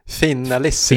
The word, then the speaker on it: finalissima: In the the big one, fin finalissima.